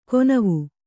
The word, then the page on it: ಕೊನವು: konavu